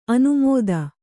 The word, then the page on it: ಅನಾಮತ್ತು: anāmattu